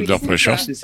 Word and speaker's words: Under Pressure: Alors, je ne sais pas si je le prononce bien. À peu près, oui, Under Pressure. – C'est ça.